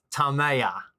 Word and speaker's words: Tamaya: What's the correct pronunciation so we can set the record straight? – Tamaya. – Yeah. Okay, Tamaya. Good.